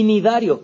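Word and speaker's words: cnidarios: Pues fijaos, la clave es, de estos primeros grupos, los que tienen una C que no se pronuncia, ¿vale? Tenóforos con C delante y nidarios con C delante son en los que aparece la neurona.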